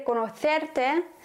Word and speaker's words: conocerte: Estoy muy encantada de conocerte.